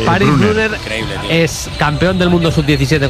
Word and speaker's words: Brunner: Paris Brunner, con dos N's, Brunner.